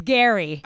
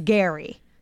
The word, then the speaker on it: Gerry: So then we get brought out Jerry, or it's Gary. – It's Gary. – It's spelled G-E-R-R-Y, but it is Gary.